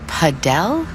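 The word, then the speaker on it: padel: Wait, is that Paddle? – No, that's paddle. Rhymes with saddle.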